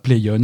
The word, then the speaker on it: Plaion: Euh, donc voilà, la news c'est ça, CorMedia change de nom et va s'appeler Playon à partir de maintenant. Playon, ça s'écrit P-L-A-I-O-N.